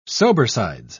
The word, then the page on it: sobersides: English; British English